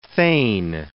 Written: thane

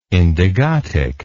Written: indigotic

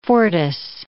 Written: fortis

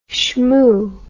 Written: schmoo

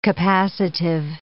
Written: capacitive

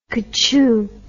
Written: kachoo

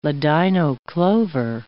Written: ladino clover

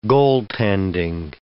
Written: goaltending

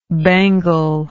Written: bangled

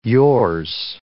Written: yours

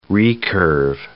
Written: recurve

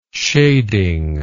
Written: shading

